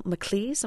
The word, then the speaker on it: Maclise: The title of the CD, Unrepentant Geraldines, comes from an etching, I understand, by a 19th century Irish artist named Daniel MacLise. Am I saying that right? – Yes, you are.